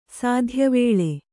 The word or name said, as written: ಸಾಧ್ಯವೇಳೆ